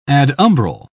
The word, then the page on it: adumbral: British English